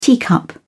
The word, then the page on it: teacup: British English